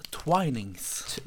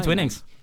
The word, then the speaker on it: Twinings: Jeg tror du uttales Twinings. – Twinings? Ikke Twinings.